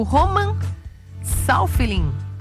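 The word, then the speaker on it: Roman Safiullin: João Fonseca perde para o russo Roman Saufelin. É assim a pronúncia, Felipe Bueno? – Ah, eu estudei russo muitos anos da minha vida e chancelo a sua pronúncia.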